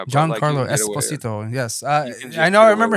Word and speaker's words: Giancarlo Esposito: It's Giancarlo Esposito. – Yeah, Esposito. Giancarlo Esposito.